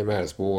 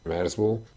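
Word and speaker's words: Merzbow: Merzbo 1979 óta több száz felvételt jelentetett meg. A projekt neve Cool Schlitzer Merzbo című alkotásából ered, és a kiejtése is megegyezik azzal.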